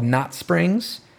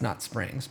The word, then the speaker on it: Knot Springs: It's Knot Springs, but it's like K-N-O-T Springs, which I should have realized. – That's more silent K.